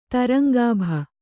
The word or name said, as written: ತರಂಗಾಭ